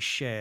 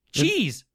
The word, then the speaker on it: Chez: He says, I would correct Ben's pronunciation on the URL for the Chez Apocalypse fan Tumblr. It actually should be Chez Apocalypse. – Chez. – Chez. – Chez. – Chez. Cheese.